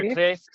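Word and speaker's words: Kreeft: You know, it's the argument from desire that Peter Kreft, how do you say lobster? Peter Kreft.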